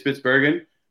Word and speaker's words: Spitsbergen: And I know didn't you say a couple guys from Germany came all the way up to Spitsbergen? Am I saying that right? To record with you? – Yeah. So, it is Spitsbergen,